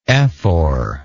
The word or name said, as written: ephoralty